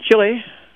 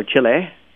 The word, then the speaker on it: Chile: Anyway, they're going to build this on a mountaintop in Chile, or Chile, if you want to go with my bad pronunciation. Except right. – I pronounced it okay if I was speaking English, which I am.